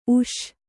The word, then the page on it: ಊಸು: kannada